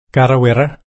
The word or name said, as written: karrawirra